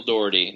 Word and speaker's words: Dougherty: Michael Dougherty. Did I say it right? – Michael Dougherty.